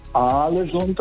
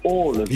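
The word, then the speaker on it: Ålesund: Und zwei A's werden als O ausgesprochen und ein A mit einem Kringel oben drauf ist eigentlich auch eine Aussprache, ein O. Also das heißt nicht Aalesund, das heißt Aalesund.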